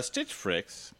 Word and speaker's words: Stitch Fix: I think they probably would appreciate it more if you pronounced the name of the company correctly, since yeah. I don't believe that Frix, F-R-I-X, is a word. – Stitch Fix.